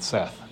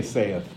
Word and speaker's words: saith: I guess he would have pronounced it Seth. We say saith, but he would have said Seth.